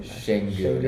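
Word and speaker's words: Şengün: Sengun. It's like a SH.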